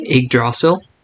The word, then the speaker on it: Yggdrasil: We're going to be talking about the world of Yggdrasil. Is that how you pronounce the tree's name? – Yggdrasil.